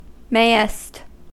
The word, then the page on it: mayest: American English